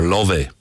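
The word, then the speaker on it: Laufey: Her name is Lofey. Or Love. Love is actually, I think, is how they pronounce it in Iceland.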